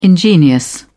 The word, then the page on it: ingenious: British English